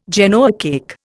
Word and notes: Genoa cake: Indian

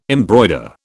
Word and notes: embroider: British English

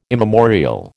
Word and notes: immemorial: American English

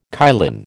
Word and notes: kylin: American English

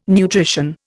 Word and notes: nutrition: Indian

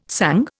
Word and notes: sung: Indian

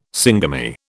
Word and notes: syngamy: British English

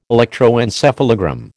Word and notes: electroencephalogram: American English

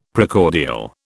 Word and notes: Precordial: British English

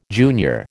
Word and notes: junior: American English

